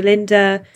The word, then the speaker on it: Linder: So it's Saoirse Linda. So Saoirse is spelt nothing like it sounds. It is spelt Saoirse Linder, is Linda, my surname.